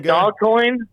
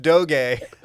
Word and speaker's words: Doge: What is it, Dogcoin? – Dogcoin. Yeah, that's the best one. – Dogcoin. – Yeah, Dogecoin. I like Dogecoin. If I was gonna invest, it's pronounced Doge. Dogecoin.